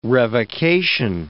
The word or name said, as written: revocation